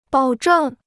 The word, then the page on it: 保证: bǎo zhèng